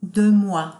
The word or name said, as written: de moi